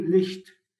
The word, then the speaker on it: Licht: يعني نطقها في الألماني licht، لكن ثقل اللفظ licht ثقيل جداً على الأذن.